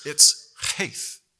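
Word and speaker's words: Heth: It's heith. Can you get a little German in you? Go heith. Heith.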